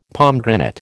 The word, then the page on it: pomegranate: American English